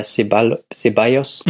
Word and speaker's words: Ceballos: Wen ich sehr, sehr interessant fand, war der andere Achter neben Saul, war der Ceballos, mhm.